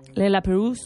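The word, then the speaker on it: Le Lapérouse: Por lo demás, hoy también tenemos un transatlántico que ha llegado a las 7 de la mañana, también nombre francés, Le La Perouse. – Sí, pero no sé si lo he pronunciado bien. – Muy bien, lo has dicho muy bien, sí.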